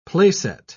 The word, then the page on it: placet: British English